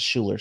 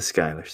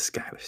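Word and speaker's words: Schuyler: Or actually, it's not the Skylar sisters, it's the Schuler sisters. Or is it the Skylar sisters? – It's the Skylar sisters, man.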